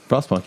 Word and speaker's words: Frostpunk: Frostpunk. Yeah, you're right. – Yeah, Frostpunk. You said it right.